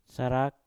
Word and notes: sarak: mpur

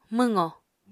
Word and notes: mwqo: Apatani

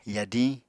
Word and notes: yadiñ: Apatani

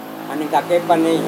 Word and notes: aningkakeipanei: Serewen

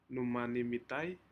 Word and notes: numana midae: Aneityum

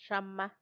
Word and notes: xama: Macuiltianguis

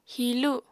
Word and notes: hilu: Apatani

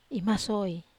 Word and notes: maso(i): Wamesa

